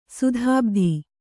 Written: ಸದ್ಬೋಧ

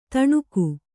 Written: ತಣುಕು

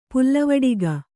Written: ಪುಲ್ಲವಡಿಗ

